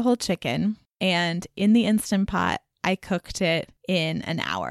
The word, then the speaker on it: Instant Pot: He said that I say it like Instapot when it's actually Instant Pot. So listeners, just be aware that that's what it actually is.